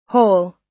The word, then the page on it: hole: American English